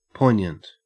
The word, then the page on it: poignant: Australian English